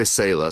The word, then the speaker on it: Selah: Is it Salers wines? – There's a couple different ways to say it. We say Selah, so it means to pause and reflect.